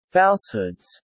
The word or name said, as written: falsehoods